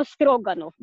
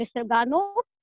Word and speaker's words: Stroganov: Então, finalmente, a família Stroganov ah, em russo se pronuncia como Stroganov, mas se pronuncia no mundo inteiro de, eh, de maneira francesa, com acento na última sílaba.